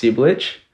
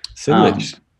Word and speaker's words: Cibilic: I think it's actually pronounced Cibilic. – Cibilic. – Yeah, it's Croatian.